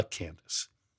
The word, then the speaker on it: Candace: And I said that right, it's the Candace.